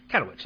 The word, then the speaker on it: Katowice: So in, I think it's probably pronounced Katowice, but K-A-T-O-W-I-C-E. Katowice? Katowice. Let's say Katowice.